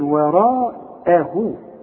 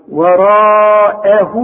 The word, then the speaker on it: وراءه: عندي واو صغيرة بعد هاء الضمير دي علشان تمد هذا الحرف حركتين، وراءه وهو الحق. أعلمك إزاي تنطق وراءه إزاي. ورقاء را مد شفايفك على هيئة قبلة، والمد بتاعها كمان مفخم، وراء رجع شفايفك على هيئة ابتسامة، وراءه واخد بالك، وهو الواو اهي مرققة.